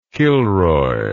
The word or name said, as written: kilroy